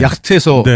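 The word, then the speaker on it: yacht: 네 Y A C H T 그러니까 이거를 어떻게 왜 이걸 요트라고 읽는지 전혀 알 수가 없는 그런 말이에요.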